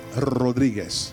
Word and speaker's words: Rodriguez: His name was Felipe Rodriguez. Can you say it like that? I didn't hear no rolling R. Say, Felipe? That's easy. Now try this. Say, Rodriguez.